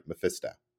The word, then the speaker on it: Mephisto: Mephisto is basically how you pronounce it. It was pronounced in the second episode of Disney Plus Marvel 616 docuseries, and that's how it's pronounced.